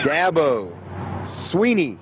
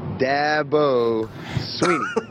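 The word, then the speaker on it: Dabo Swinney: Dabo Sweeney. Dabo Sweeney.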